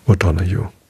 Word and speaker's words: O'Donoghue: Es heißt Die Erscheinung des O'Donoghue. Ja. O'Donoghue. So würde ich es aussprechen. D-O-N-O-G-H-U-E. Ja.